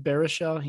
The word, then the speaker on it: Baruchel: It's either two or three punches because he takes out your boy, Jay Baruchel. Is that how you say his name? I don't know. – Yeah, Jay Baruchel. Baruchel. Yeah, that's correct.